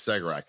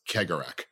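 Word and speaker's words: Cegorach: It's spelled Segarak to any American because it's a C-E, is the first two letters. I always found it as Segarak, and then people kept telling me, no, it's Kegarak.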